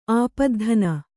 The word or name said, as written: ಆಪದ್ಧನ